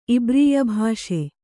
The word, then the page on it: ಇಬ್ರೀಯಭಾಷೆ: ibrīyabhāṣe